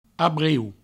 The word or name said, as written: abrieu